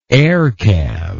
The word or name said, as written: air cav